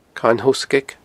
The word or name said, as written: Carn Thoghsgaig